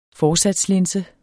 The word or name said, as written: forsatslinse